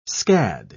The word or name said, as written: scad